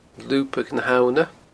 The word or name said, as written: Lubadh na h-Abhna